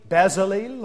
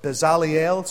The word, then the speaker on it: Bezalel: And the Lord spake unto Moses, saying, See, I have called by name Bezalel, or Bezaliel, some pronounce it, the son of Uri, the son of Hur, of the tribe of Judah.